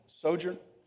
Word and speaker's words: sojourner: Maybe you say sojourner. I can't say it that way, but sojourner, I don't know. I have to place the emphasis on the first syllable.